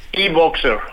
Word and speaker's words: e-Boxer: ¿Qué tanto los compradores tienen que hacer y esperar por tener su e-Bowser? E-Bowser, ¿no? Así se pronuncia. – E-Boxer. – Boxer. – E-Boxer. Boxer. Bueno.